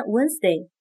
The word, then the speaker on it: Wednesday: 星期三（Wednesday）曾经的写法是 Wonder Day，就是以沃登的名字命名的。虽然这个单词里的字母 D 不发音，但是它还是一直存在的。